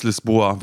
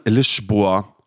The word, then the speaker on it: Lisboa: Und hier in Portugal würde man denken, heißt es Lisboa, weil das überall ausgeschildert ist. Ist aber falsch ausgesprochen. Man macht dann Zischlaut hin, Lisboa heißt die Hauptstadt von Portugal.